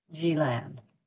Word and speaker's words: Gjilan: And the name of that city is spelled G-J-I-L-A-N, Gjilan.